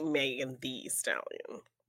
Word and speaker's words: Megan Thee Stallion: You're supposed to say Megan Thee Stallion. – Just to emphasize that she is a stallion.